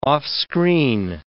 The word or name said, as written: offscreen